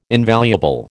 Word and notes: invaluable: American English

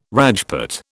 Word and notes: Rajput: British English

